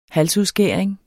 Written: halsudskæring

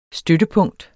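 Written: støttepunkt

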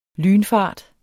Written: lynfart

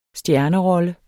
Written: stjernerolle

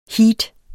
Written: heat

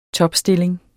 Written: topstilling

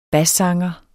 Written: bassanger

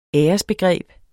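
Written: æresbegreb

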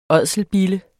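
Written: ÅDSELBILLER

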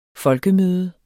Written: folkemøde

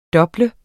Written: DOBL

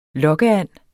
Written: lokkeand

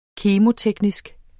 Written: kemo-

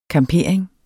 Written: campering

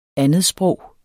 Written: andetsprog

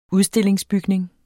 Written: udstillingsbygning

